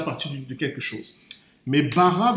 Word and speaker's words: bara: En hébreu, ça s'écrit bara, B-A-R-A.